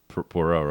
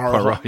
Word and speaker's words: Poirot: maybe we're basically being like Hercule Poirot, right? – Poirot. – Yeah, I can't say it right.